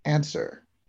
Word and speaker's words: answer: you would say answer. Since the W in answer is silent, all of the W's in the clue are also silent.